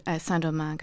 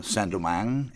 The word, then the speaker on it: Saint-Domingue: Saint-Domingue, is that, that's about as close as I can get to a French pronunciation, now referred to as Haiti.